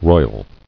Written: Roiling

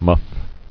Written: Muffing

